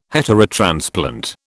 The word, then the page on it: heterotransplant: British English